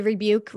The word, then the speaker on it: Rebuke: Is it Rebuke? Is that how you say his name? I think it's, yes, Rebuke. – I don't know. The Rebuke remix that came out towards the later end of the year.